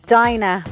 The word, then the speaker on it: Dinah: It's Dinah from County Kerry, D-I-N-A-H.